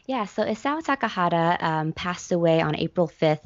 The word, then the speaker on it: Isao Takahata: Tell me about Isao Takahata.